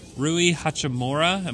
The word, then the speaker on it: Rui Hachimura: I just doubt it, but it's Rui Hachimura.